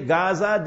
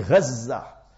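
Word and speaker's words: Gaza (غزة): You say Gaza, that's how the Westerners pronounce it. In Islamic terminology, Gaza.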